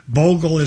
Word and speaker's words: Bogle: Bogle is spelled B as in blessed, O-G-L-E.